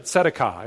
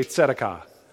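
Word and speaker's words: tzedakah (צדקה): The Hebrew version of it, tzedakah. Everybody say tzedakah. You can see it up there. T-S-E-D-E-Q-A-H is how we would spell it if we were transliterating it into English. Tzedakah.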